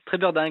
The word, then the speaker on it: Trébeurden: Avant de me fâcher avec tous les Bretons, j'ai bien prononcé, c'est Trébeurden ? – Trébeurden, exactement.